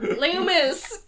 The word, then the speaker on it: Lumos: Lumos.